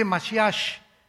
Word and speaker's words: Mashiach: Se escribe Mashiach, Jesús el Mesías en el hebreo, pero es se pronuncia la CH, Yeshua Mashiach, y siempre pronuncian dándole mucho énfasis a la palabra J en el hebreo.